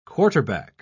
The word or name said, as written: quarterback